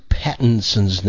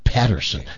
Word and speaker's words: Pattinson: He always mispronounces Robert Pattinson's name. He calls him Robert Patterson. No, it's Pattinson.